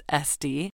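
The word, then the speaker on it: S'te: When Jeremy was 4 years old, his sister Estee was born, spelled S-apostrophe-T-E, like the French abbreviation for the word saint, but pronounced Estee, to Diane and the new man in her life, who I believe's name is John Bright.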